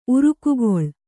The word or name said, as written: ಉರ್ಗುಗೊಳ್